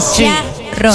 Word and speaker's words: Seattle: Seattle, bolito. Seattle. Pronuncie bien. Es Seattle.